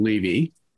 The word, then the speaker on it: Levy: By the way, I um, I pronounced my last name Levy, just Thank so you, you, know.